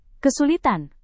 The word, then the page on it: kesulitan: indonesian